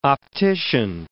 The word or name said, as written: optician